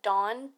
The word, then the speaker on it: Donn: On Instagram, you can look up Dawn, D-O-N-N, on the rocks.